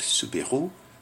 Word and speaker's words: Soubirous: And you have a drawing there on page 35 of Saint Mary appearing to Saint Bernadette, Saint Bernadette Soubirous, as we say her name in French, who received a private revelation of the Mother of God, Mary, who told her that she, Mary, was the Immaculate Conception.